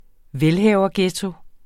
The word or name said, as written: velhaverghetto